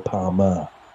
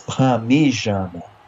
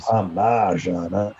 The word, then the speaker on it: Parmigiana: It makes no I say it now because that's what everyone knows, but it literally makes no sense to say Palmer. – No. – It's Parmigiana. – It is. – Parmigiana.